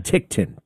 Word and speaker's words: Ticktin: T-I-C-K-T-I-N. – Tichten. Tichten.